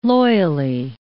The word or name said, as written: loyally